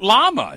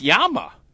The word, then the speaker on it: llama: It's not llama. It's Yama.